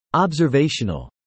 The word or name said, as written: observational